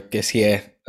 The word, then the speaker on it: Kessie: So, and and it's an area where we are deep, where we have our positions covered, and I don't know Kessi or Kessie. Uh, I think it's pronounced Kessi.